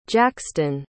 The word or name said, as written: jaxton